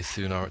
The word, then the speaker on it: Thunar: I've tried the new KDE one, whose name escapes me, Nautilus, Thunar, Thunar, however you want to pronounce it. I say Thunar, it's a single N, so Thunar.